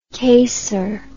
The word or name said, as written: caser